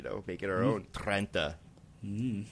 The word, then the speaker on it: Trenta: It was the Trenta. Would that be the correct pronunciation? – Trenta? I think that sounds right. That sounds okay. Oh, I like how you rolled the R there.